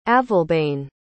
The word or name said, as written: avalbane